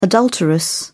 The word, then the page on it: adulteress: British English